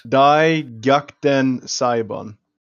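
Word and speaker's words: Dai Gyakuten Saiban: What is the the actual Wii pronunciation for it? – Die Gyakuten Sabian.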